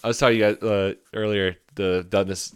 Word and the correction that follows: Denis: I know it's Denis and not Dennis.